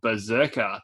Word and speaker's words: BRZRKR: Keanu Reeves is starring in and producing a Netflix live action film, an anime series adaptation of Berserker, that's spelled B-R-Z-R-K-R for your listeners at home there.